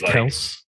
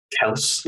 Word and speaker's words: Kelce: It's pronounced Kelce, actually. – Kels, the beardless Kels.